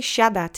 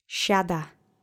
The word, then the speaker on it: siada: Let's say siada together three times. The accent is on the first syllable. Siada. Siada. Siada. Great. And I hope you remember the SI letter combination is a sh sound in Polish.